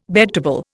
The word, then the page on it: bedtable: Indian